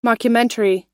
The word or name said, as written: mockumentary